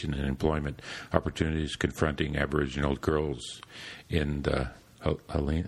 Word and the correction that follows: Hualien: uh, how do you pronounce that? Hualien. Hualien area.